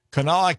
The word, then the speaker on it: Kanawha: We're talking with Ashley from the, and I think I've got this right, Sleep in Heavenly Peace, Kanawha County Chapter. Am I saying that right? – Yes, sir, you are.